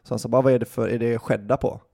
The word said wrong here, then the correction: cheddar: He said like, oh, is it skedda? It's actually pronounced cheddar.